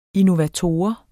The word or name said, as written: innovator